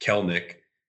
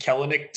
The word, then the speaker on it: Kelenic: I mean, it's when you look at it, when I first saw it, I said Kelenic too, because that's what it looks like. – Yeah, well, there's an E in there. – I know. – If there wasn't an E between the L and the N, then I wouldn't give a shit. Yeah. I'd be like, oh, Kelenic, no problem. The E is silent, apparently.